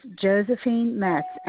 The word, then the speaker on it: Josephine Metz: It's Josephine Metz, M-E-T-Z.